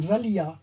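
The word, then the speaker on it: valiha: Ça s'appelle une valia. Ça s'écrit V-A-L-H-I-A.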